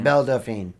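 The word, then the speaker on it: Belle Delphine: Bella Delphine.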